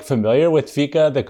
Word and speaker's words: fika: But okay, Fika, got it. – Fika.